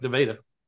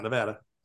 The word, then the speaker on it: Nevada: Much like Nevada, not Nevada.